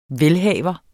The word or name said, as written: velhavers